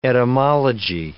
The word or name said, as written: etymology